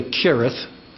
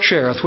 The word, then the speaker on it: Cherith: Now I am aware that the proper pronunciation of this word is really Kirith, Kirith.